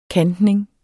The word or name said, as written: kantning